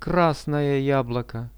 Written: krasnoje jabloko

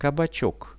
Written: kabachok

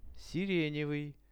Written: sirenevyj

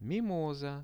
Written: mimosa

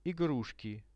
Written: igrushki

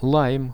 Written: laim

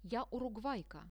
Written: ja urugvaika

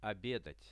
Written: obedat

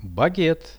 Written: baget

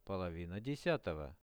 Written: polovina 10-ogo

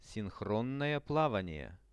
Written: sinhronnoe-plavanie

